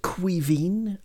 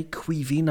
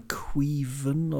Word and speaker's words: Caoimhín: Um, apparently, you pronounce it Quivine, okay? Quivine. I believe I called him Quiven or something before, but Caoimhín Kelleher.